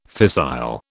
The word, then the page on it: fissile: British English